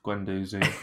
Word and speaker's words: Guendouzi: Despite a bad Arsenal performance, though, there was one pretty positive thing for Arsenal, and that was a youngster, Guendouzi, what we're calling him. – Guendouzi, right? – Yeah, way it's spelled, Guendouzi.